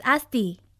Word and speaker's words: Asti: My name is Asti. A-S-T-I.